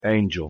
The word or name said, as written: angel